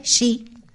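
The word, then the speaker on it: shi (四): Fíjate tú que es que la pronunciación para los japoneses, yo no sé pronunciarla, pero para ellos es shi. Yo lo voy a pronunciar así, sí, shi, que shi en japonés significa muerte.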